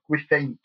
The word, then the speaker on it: Gwythaint: And then I think, I mean, the Gwythaint, I think they're called, the pronunciation is more like Gwythaint or something like that in the film. But Gwythaint is a Welsh word, which means some sort of predatory bird.